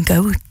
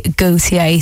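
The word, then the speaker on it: Gotye: I have been calling him Goat. I thought that's how you pronounced it. But no, it is actually Gautier.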